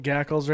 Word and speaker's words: Gackle: Like Gackles right down there? – Yeah, Gackles kinda. Down there. Rhymes with cackle.